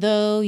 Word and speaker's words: though: Though aqui, T-H-O-U-G-H, atenção à pronúncia dessa palavra que não é difícil. É só você fazer aí a ligação entre o que você está lendo e a sonoridade. Though, então o finalzinho ali é mudo, tá?